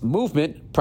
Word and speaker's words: MVMT: With fair prices, unexpected colors, I didn't see that coming, and clean original designs, Movement, pronounced Movement, it's MVMT, grew into one of the fastest growing watch brands, shipping to over 160 countries across the globe.